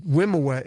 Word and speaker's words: Wimoweh: Wimowa is how you pronounce it, and W-I-M-O-W-E-H. It's not anything else, but...